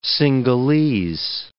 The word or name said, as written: Singhalese